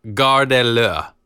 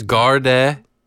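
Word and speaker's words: Gardez l'eau: It's a two-syllable word. – Yeah, garde. It's G-A-R-D-E-Z. L apostrophe E-A-U.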